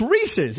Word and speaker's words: Reese's: And I kept saying, if I say Reese's, they're not going to pay us because that's not the word. It's Reese's. It's Reese's peanut butter cups.